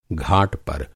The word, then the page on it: घाट: گھاٹ